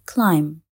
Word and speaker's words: climb: فكر في الحرف B بكلمة climb أو الحرف G بكلمة foreign.